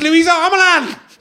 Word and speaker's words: Luisa Omielan: It's Luisa Omielan.